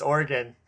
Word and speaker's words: Oregon: You know, the pronunciation of his state, Oregon. – No. Whoa. It's Oregon.